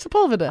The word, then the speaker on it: Sepulveda: So I still can't say Sepulveda. Is that how you say it? – Sepulveda. – Oh, I said it right.